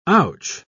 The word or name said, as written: ouch